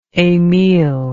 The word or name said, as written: emile